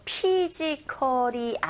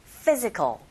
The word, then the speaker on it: physical: 자, 모두 다 강세가 가는 단어들인데 정관사 더를 제외하고 자, PH가 F 발음 나는 거 아시죠? 그래서 피지컬이 아니라 physical aspect overwhelms the wetness가 아니라 wetness 이런 식으로 발음하시면 됩니다.